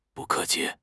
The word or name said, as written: bu4 ke4 qi5